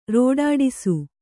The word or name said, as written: ರೋಡಾಡಿಸು